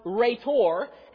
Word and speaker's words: rhetor: The title orator is translated from the Greek word rhetor, and that is spelled in the English for us, it'd be R-H-E-T-O-R.